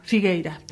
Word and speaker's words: Figueira: Es Maitena Muruzábal, Muruzábal con Z, perdonadme, y Candela Figuera. – Entonces, Figueira. – Figueira, perdón. Candela Figueira.